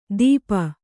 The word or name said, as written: ದೀಪ